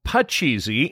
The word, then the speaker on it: Pachisi: It's a cross and circle game called Pachisi in India. It's spelled P-A-C-H-I-S-I.